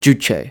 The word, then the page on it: Juche: English